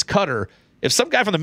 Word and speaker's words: Qatar: Love the show, but just want to let you know it is pronounced Qatar." – Interesting. – And he spelled it out phonetically.